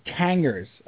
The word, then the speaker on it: Tanger: You're at the Tangers of microphones right now. Tangers? Is it Tangers? Tangers? – Tanger. – It is Tanger. – That's the outlet mall, yes, Tanger. – So we're referring to the outlet malls on Long Island.